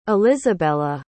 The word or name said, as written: elizabella